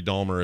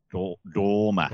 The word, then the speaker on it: Dahmer: Dahmer. – How do you say it? – There's no L. – Oh, I always thought it was Jeffrey Dahmer. Is it? – It's an H, isn't it? D-A-H-M-E-R? – Dahmer?